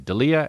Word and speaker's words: Delia: And I said D-E-L-I-A, and he said, "Can you spell that again, please?" I said Delia, D-E-L-I-A, and he said, "Oh, so Delia," and I said, "Sure," because why would you argue with somebody that you never met and don't even know what they look like?